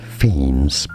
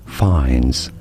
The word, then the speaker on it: Fiennes: So he says it as Ralph, Ralph, and then his last name is not Fiennes, but rather Fiennes.